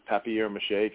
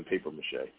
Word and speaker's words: papier mâché: Because I always thought it was, I pronounce it maybe because I'm from Boston, paper mâché. So I'm probably pronouncing it incorrectly. – Well, originally French, so it's spelled like okay. Papier mâché, and usually it has accents when I've seen it. But I did go to French school, so maybe I just learned it the French way.